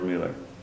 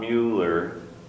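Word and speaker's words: Müller: If you have seen the Torchlighters as a kid, there's at least a couple episodes of old George Mueller. His actual proper pronunciation, since he was born in Germany, is not Mueller, but Mueller.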